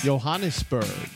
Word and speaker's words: Johannesburg: Johannesburg. – Johannesburg. – J-Berg. – South Africa. – Is it Johannesburg or Johannesburg? – Johannesburg. – Joe with a hard J.